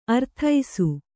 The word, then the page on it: ಅರದೇಸಿ: kannada